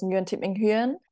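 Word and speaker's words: Nguyễn Thị Minh Huyền: Full name and the Vietnamese pronunciation is Nguyen Thit Minh Huyen.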